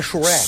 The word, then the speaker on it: Shrek: Xereque. Não Xereque. É Xereque, não é K. – É, exatamente. – Gente, vamos fazer o seguinte, tá muito bom. – Olha, gostei. – A pronúncia é Shrek.